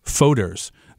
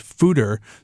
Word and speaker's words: foeder: This is a mixed fermentation ale that has been aged in oak foeders. Now, that's an interesting word. I say foeder, but it's actually pronounced fooder. So I even messed it up, but it's F-O-E-D-E-R.